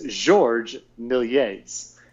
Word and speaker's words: Georges Méliès: And this is, I specifically looked this up online, how to pronounce this. It's Georges Méliès.